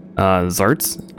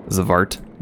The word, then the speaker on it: Xvart: Zarts. I call them Zarts. I don't know if I'm pronouncing it right, but Zavart. Zavart maybe is maybe the better pronunciation. The name is spelled XVART.